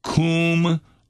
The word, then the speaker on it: Cwmrhondda: Yes, it's pronounced Qumrantha. That means the Valley of the Rhonda.